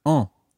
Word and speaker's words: en: And that other pronoun is en. – En. – Now, that's spelled E-N, en.